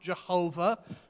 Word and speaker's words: Yahweh: And that's the name Yahweh. That's my best stab at a pronunciation. It's often pronounced Jehovah, which really turned up in the English Bible in the 16th century.